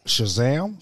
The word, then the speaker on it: Shazam: Shazam.